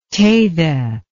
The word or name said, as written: Teyde